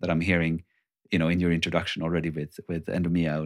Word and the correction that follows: Eudaimonia: and Oikos. Like it's already there, like I said it wrong. What is it? Eudaimonia.